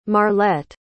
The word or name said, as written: marlette